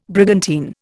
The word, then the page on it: brigantine: Indian